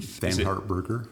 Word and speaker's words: Thanhardt-Burger: By the way, uh, the Thanhardt-Burger.